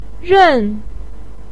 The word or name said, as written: ren4